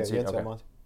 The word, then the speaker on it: Yency Almonte: Yeah, Yency Almonte.